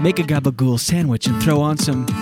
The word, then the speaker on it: gabagool: Gabagool, by the way, is capicola. But that's how they say it.